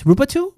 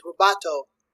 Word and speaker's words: rubato: How do you say this? Rubato? Rubato? I think it's rubato.